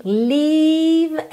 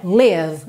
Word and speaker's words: leave: E lembra que leave é uma palavra de ressonância. Essa sílaba é compridinha, ok? Não é live, live, para não confundir com morar ou viver, live. Aqui a gente tem leave, leave.